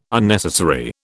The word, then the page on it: Unnecessary: British English